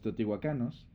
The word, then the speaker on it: teotihuacanos: pero sobre los teotihuacanos, o te, ¿cómo se dice? Teotihuacanos. teotihuacanos, está bien dicho.